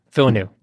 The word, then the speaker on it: Villeneuve: I'm going to butcher the name. – Villeneuve? Villeneuve. – Yeah, Villeneuve. Thank you.